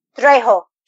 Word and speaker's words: Trejo: Trejo. – Trejo. – Yeah, Trejo.